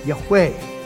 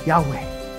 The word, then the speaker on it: Yahweh: We have YHWH, and that is best pronounced Yahweh. Yahweh. But that we're guessing on the vowels.